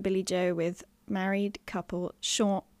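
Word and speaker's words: Siôn: His name is Sean, but it's written S-I-O-N, which is the Welsh spelling of Sean. So I just tripped up there because I'd written married couple Sean, brackets pronounced Sean.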